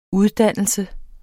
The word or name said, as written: uddannelse